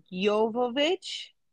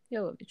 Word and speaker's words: Jovovich: I mean, so how you pronounce this is Jovovich. She is apparently Ukrainian. – Oh, okay. Jovovich.